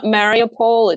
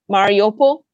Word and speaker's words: Mariupol: It's not Mariupol, it's Mariupol.